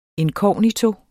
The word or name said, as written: inkognito